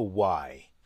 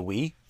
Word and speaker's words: Wii: And a dude comes up and gets up to him and goes, Nintendo, why? He mispronounced the Wii.